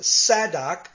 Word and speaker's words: Zadok: That word Sadducee, it's from the Hebrew word Zadok, which in Hebrew is pronounced Sadok, and it's kind of like the Greek form we find then, Sadducee.